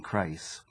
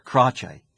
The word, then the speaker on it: Crace: He said, Crace. And I said, I beg your pardon. He said, it's pronounced Crace.